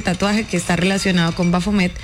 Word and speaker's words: Bafomet: De hecho, se habla de que, bueno, Bafomet, así se pronuncia aquí, es una figura simbólica que suele representarse con cabeza de cabra, cuerpo humano y elementos asociados a lo masculino y femenino.